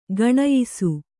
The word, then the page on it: ಗುಣಿಯಿಸು: guṇiyisu